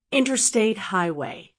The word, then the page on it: interstate highway: American English